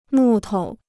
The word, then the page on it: 木头: mù tou